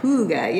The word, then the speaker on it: Hygge: Did I pronounce it right? Hygge? – Hygge, yeah.